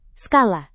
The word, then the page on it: skala: Indonesian